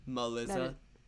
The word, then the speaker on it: Muhlizza: Like, it's spelled M-U-H-L-I-Z-Z-A. Oh. Melissa.